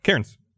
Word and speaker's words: Cairns: We went up to a place called, it's on the map, it's called Cairns, C-A-I-R-N-S. – Yeah. – How do you pronounce that? – Cairns.